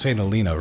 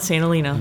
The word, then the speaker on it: St. Helena: All right, we are in the lovely town of St. Helena, and I'm actually, it's pronounced St. Helena, right? That's what I hear the locals call it. – That's what we call it, St. Helena. – It's all kind of one long word.